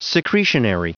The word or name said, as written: secretionary